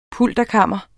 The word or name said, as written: pulterkammer